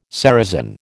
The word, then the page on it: ceresin: American English